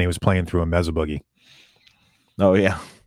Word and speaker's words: Mesa Boogie: Mesa Boogie.